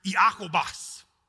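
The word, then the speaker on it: Iakobos: James is actually not the way it would have been pronounced in the Greek that the New Testament was written in. It actually was pronounced Iakobas.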